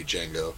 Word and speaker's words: Django: and we had been a band called Django with the silent D, Django,